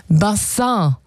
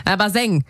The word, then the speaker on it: Bassin: Also statt Bassin wurde es irgendwann Baseng.